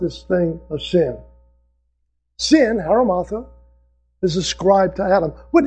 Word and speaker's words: hamartia: The Greek word for sin is a word, haramartha, is the way it's pronounced, as best I can tell. H-A-M-A-R-T-I-A.